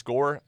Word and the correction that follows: Gorey: I guess, is that how you pronounce the name? – Gorey.